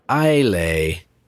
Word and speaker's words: Islay: I think people were up in arms about Lord John's pronunciation of the word Islay, which I think is supposed to be Isla. It's either Ile or Ila, I believe, depending on how you pronounce it.